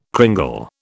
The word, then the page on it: cringle: British English